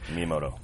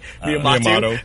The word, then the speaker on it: Miyamoto: Miyamoto. – Miyamoto. What did I say? – Miyamoto.